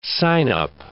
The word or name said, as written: sign-up